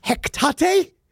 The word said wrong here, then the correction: Hecate: Is that how you pronounce it? – Probably. – the goddess? – The goddess, the witch? – The yes, witch the witch goddess? – Hecate. – Hecate? – Yeah.